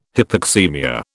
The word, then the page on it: hypoxaemia: British English